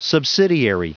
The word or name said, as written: subsidiary